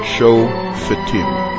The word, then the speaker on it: Shofetim: When I talk English, I say it the Englishized way, and that is Shofetim. But the correct pronunciation, Shofetim. The Fay, with its shva na, belongs to the last syllable, tim, not to the first syllable, sho.